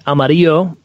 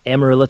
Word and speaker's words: Amarillo: It looks like Amarillo, but it's Amarillo, Texas.